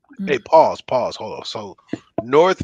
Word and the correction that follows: Norse: mythology. Wait, wait, wait. Did I say that right? Norse, uh, Vikings.